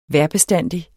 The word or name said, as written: vejrbestandig